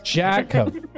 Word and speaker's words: Jakga: Okay. Jacka. It's spelled J-A-K-G-A. Jacka. – G? Now you G is silent. – A? – J-A-K.